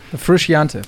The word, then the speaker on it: Frusciante: Frusciante. – Frischiante, ja, genau.